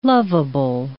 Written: loveable

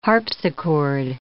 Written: harpsichord

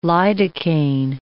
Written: lidocaine

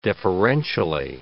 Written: deferentially

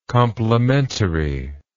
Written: complimentariness

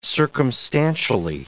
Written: circumstantially

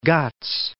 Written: Western Ghats

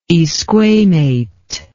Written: esquamate

